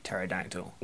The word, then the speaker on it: pterodactyl: but with a silent P at the front, like pterodactyl.